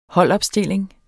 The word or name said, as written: holdopstilling